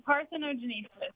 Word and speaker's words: parthenogenesis: Parthenogenesis, is that how it's pronounced? – Yeah, parthenogenesis.